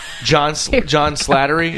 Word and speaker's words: John Slattery: Like, what if somebody like, okay, for example, John Slattery is that him?